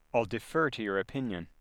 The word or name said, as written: defer